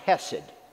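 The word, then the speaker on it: hesed (חסד): And it's the Hebrew word that's pronounced hesed.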